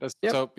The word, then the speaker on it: Asobi: Surprised that like we haven't seen anything from Team Asobi, is it pronounced? – Yep. – Asobi, yeah. Yeah, because Astrobot's amazing.